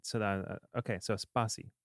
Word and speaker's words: Spatie: Is there a t sound at the end of the name of your company or not? Is it purely just Spatie? – Yeah. – Sometimes I hear a little T and sometimes I don't. So no, it's Spatie. It's like your pronunciation for Spatie is like 10 out of 10. It's perfect.